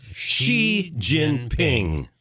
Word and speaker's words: Xi Jinping: How is this pronounced? – Xi Jinping.